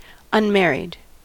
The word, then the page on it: unmarried: American English